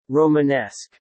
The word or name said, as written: Romanesque